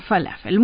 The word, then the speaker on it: falafel: Falafel. – Exactamente así se pronuncia. – Ah, falafel.